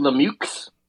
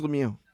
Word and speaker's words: Lemieux: What is it, Mario Lemieux? – No, it's Lemieux. But I could see why you might think Lemieux.